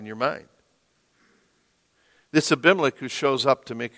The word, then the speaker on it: Abimelech: Abimelech. Abimelech in Hebrew is what it would be pronounced.